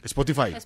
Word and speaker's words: Spotify: La aplicación de música Spotify, ¿se dice así? – Spotify. – Ah, o Spotify. – Spotify. Spotify. Publicó que el artista más oído en Colombia en el 2015. Spotify. Sí, Spotify. Publicó que el artista más oído en Colombia en el 2015 fue Carlos Vives, con 16 millones de reproducciones.